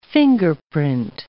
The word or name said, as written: fingerprint